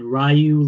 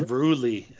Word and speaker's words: Ryu Lee: I think it's pronounced Ruli, I think is Ruli? What they're saying. I think they were saying Ruli on – I know some people say Ryu and some people are saying – but on commentary it was Ruli.